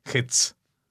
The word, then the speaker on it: Hetz: No te esfuerces en pronunciarlo como Z, sino es el sonido tz, Hetz. No sé, o sea, como no hay forma de hacerlo, por eso se escribe así.